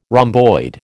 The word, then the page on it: rhomboid: American English